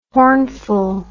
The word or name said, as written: hornful